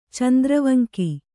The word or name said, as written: ಚಂದ್ರವಂಕಿ